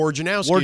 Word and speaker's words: Wojnarowski: Wojnarowski is how you say it.